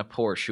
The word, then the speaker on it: Porsche: I always say Porsche, and I know that's not right. – Yeah, it doesn't matter. In England, they say it like that, and we're supposed to say Porsche. I mean, that's a German pronunciation, but it doesn't matter.